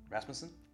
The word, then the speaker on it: Rasmussen: Rasmussen? – That's it.